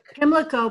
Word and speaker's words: Pimlico: Pimlico Park.